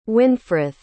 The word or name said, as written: wynfrith